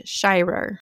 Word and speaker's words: Shirer: His last name is spelled S-H-I-R-E-R, and it's Shirer.